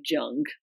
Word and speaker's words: Jung: So one of my favorite psychotherapists, his name is Carl Jung, and it's spelled J-U-N-G. So some people say Jung, but it's Jung.